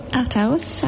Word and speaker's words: Art House: Alors, je suis à la galerie Art House, c'est comme ça qu'on prononce ? – Oui, Art House, à Auric Village.